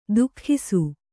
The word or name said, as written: ತೂಗಿಸು